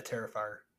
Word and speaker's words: Terrifier: Is it Terrifier? – Yeah, Terrifier. Yeah.